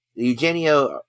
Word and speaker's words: Eugenio: uh eugenia eugenio derbez that's his name